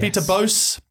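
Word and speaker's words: Peter Bosz: And this week it is Peter Bosch. That's how to pronounce his name? – Yeah. – Yes. Peter Bosch.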